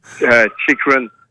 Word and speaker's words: Chychrun: Le jeune et prometteur, Jacob Chychrun, comment qu'on le prononce, baptême? – Chychrun.